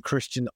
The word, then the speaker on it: Kristian: Kristian Ultra,